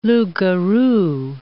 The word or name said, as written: loups-garous